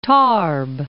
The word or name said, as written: Tarbes